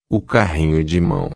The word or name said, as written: carrinho de mao